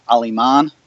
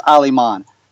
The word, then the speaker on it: Aliman: So my last name's pronounced Aliman. – Oh, that's how you say it. So close. – But here's the deal. That's how you say German and Spanish, all right? it's pronounced Aliman.